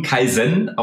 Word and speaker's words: Kaizen: Ähm Kaizen geschrieben, Kaizen ausgesprochen.